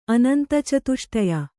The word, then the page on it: ಅನಂತಚತುಷ್ಟಯ: anantacatuṣṭaya